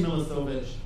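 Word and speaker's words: Milošević: Yeah, you said it wrong. It's actually Milosevic.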